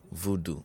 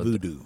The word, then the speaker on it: voodoo: Is, is that the right way to pronounce it or voodoo? Voodoo. – So voodoo. – The, the, I mean, the English word is voodoo.